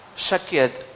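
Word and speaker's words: шакед (שָׁקֵד): Слово «миндаль» «шакед» и слово «бодрствовать» или «контролировать» «шокед» выглядит одинаково в иврите. Одинаково. Здесь пишется «шакед», а здесь «шокед».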